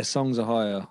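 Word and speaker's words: Songs: Ohia: Is it Songs Ohio? Am I pronouncing that right? – Yeah, Songs Ohio.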